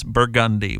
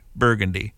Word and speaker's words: Burgundy: In New Orleans, one says Burgundy. One does not say Burgundy. You better run if you do.